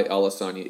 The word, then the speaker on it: Alasania: And Irakli is kind of as it sounds, I-R-A-K-L-I, Alasania is A-L-A-S-A-N-I-A. So it's kind of the simpler spelling. Don't complicate it with additional S's and other silent letters.